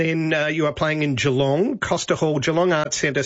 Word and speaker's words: Geelong: I thought it was Geelong. But it's Geelong, apparently.